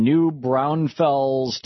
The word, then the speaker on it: New Braunfels: Tim, in New Braunfels, Texas. Hey, Tim, am I saying that right? – Yeah, you're saying it right.